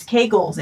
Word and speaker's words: Kegels: And it's more than I pronounced it Kegels. She's like, it's Kegels. It rhymes with bagels.